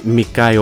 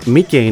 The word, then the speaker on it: Mikay: Και όχι Μικάι όπω έλεγα, όπως έλεγα στην εκπομπή τη Παρασκευή πριν από, την, ε, πριν από τι διακοπέ ε, για το Πάσχα. Μικέι είναι το, ε, η κανονική προφορά αυτού του καλλιτεχνικού ονόματο.